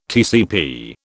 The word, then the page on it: TCP: British English